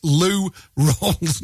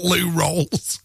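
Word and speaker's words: Lou Rawls: It's by Lou Rolls. as in R-A-W-L-S, Lou Rolls, not Lou Rolls.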